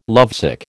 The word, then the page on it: lovesick: American English